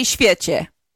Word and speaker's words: świecie: Okay, how do you say świecie? – Świecie. – Oh, there was an R in that earlier when we were off the air. You go świecie.